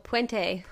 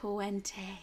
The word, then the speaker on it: Puente: Oh, Puente. – Puente.